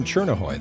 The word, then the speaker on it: Tjornehoj: Kristen Chernohoy, that's spelled T-J-O-R-N-E-H-O-J. Take a guess whether or not I had to ask her how to pronounce her name.